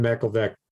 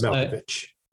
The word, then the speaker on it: Malkovich: Malkovich.